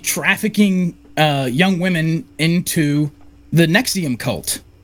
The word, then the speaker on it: NXIVM: Yeah, but it was pronounced Nexium, but it was just Roman numerals.